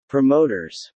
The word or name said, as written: promoters